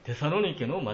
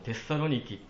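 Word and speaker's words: Thessaloniki: 余談ですけれども、 10 年ほど前に 2008 年にアテネオリンピックが行われましたけれども、その時サッカー日本代表の一つの試合が、アテネ以外の町で行われたんですが、それがテサロニケの町でした。今はテサロニキという発音の方が正しいようですけれども、それはテサロニケの町だというふうに何か思ったのを覚えています。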